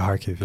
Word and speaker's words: Kharkiv: Yeah, Kharkiv.